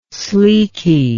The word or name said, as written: sleeky